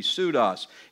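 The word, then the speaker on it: pseudos (ψεῦδος): Interesting Greek word, pseudos. It's where you might hear somebody using that word. Starts with a P when you spell it. It's a silent P, pseudos.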